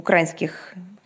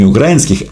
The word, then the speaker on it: украинских: А, а что касается парадов в и Луганске, они, кстати говоря, тоже упомянуты э, в украинских этих самых протестах. – Не украинских, а украинских. Но ничего, придет время, и э, в МИД России начнут э, правильно ставить ударение в словах в русском языке.